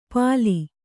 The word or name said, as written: ಪಾಲಿ